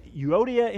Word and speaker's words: Euodia: And it's obvious that these two ladies, Euodia and Syntyche is how I pronounce their names. We'll find out in heaven if that was right or not.